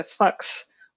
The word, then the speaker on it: Fuchs: Yeah, I love the story of, I call him Fuchs, but I've been told that in order to pronounce this properly, it's something closer to Fuchs, which is fun to say for an English speaker.